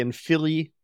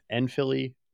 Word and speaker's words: Enfili: The Enfili are oppressed. Enfili, it's supposed to sound like infant, right? – Oh, I guess it is.